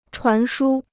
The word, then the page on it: 传输: chuán shū